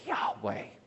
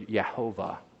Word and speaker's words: Yahweh: It's this word that we don't even know how to pronounce, but scholars have landed on the pronunciation Yahweh. You ever heard that? Yahweh. Or the German version would be Yehovah.